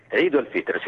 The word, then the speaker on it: Eid al-Fitr: Fonèticament la I és una I, però s'escolta perfectament Eid al-Fitr, que és el nom oficial religiós d'aquesta festivitat. El Fitr és el dijuni, és trencar el dijuni, i Eid és la festa. I llavors, sí, està ben pronunciat.